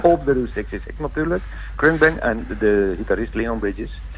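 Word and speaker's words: Khruangbin: En dat het klinkt een beetje als krung. En het wordt ook zo uitgesproken, krungbin. – Ja, krungbin, ja, inderdaad. – En het komt eigenlijk uit de Thais.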